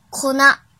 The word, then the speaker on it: 粉: 训读的时候读作 ko 或 kona，比如奶粉 kona milk，kona milk，kona milk。